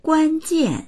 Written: guan1jian4

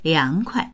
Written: liang2kuai

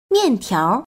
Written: mian4tiao2

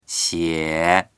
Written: xie3

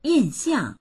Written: yin4xiang4

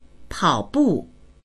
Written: pao3bu4

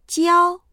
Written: jiao1

